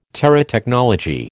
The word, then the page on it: Terotechnology: American English